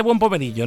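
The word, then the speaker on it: buon pomeriggio: Se dice buen pomerillo, ¿no? – Buen pomerillo, sí.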